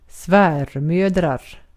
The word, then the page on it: svärmödrar: svenska